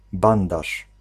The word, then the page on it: Verband: /fɛɐ̯ˈbant/